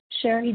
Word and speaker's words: Shari: Sherry D. – Spell that first name, please. – S-H-A-R-I.